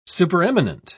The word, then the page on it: supereminent: British English